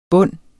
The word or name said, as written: bunde